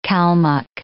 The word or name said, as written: Kalmuck